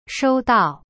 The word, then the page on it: 收到: shou dao